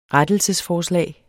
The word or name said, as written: rettelsesforslag